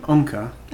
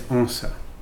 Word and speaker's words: onça: Is it onça? – But it's, well, it's descended from, it comes from a Portuguese word, because that is onça.